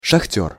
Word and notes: sahter: Russian

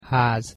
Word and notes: haz: Hungarian

